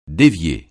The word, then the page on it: dévier: francais